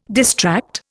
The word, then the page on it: distract: Indian